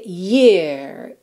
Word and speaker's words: year: A palavra ano em inglês, Y é a R, vai ser year, year.